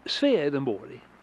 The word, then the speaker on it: Swedenborg: It's pronounced Swedenborg in Swedish.